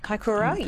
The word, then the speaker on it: Kaikoura: Kaikoura, yes.